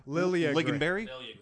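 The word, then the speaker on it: Liljegren: You have Timothy Lindgren. No, I'm messing up that pronunciation. – Lilligren. – Lilligren. Okay, I got it.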